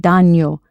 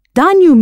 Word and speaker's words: daño: To harm oneself or to get hurt is hacerse daño, a reflexive. Daño means harm or damage and is spelled D-A-N-Y-O. That's an N with that little squiggly on top.